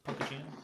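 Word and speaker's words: Punkuccino: pumpkin beers, is the Elysian Punkachino? I think is how you pronounce it. – Right? Punkachino? – Punkachino. They spell it a little weird, but I get it.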